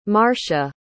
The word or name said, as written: marsha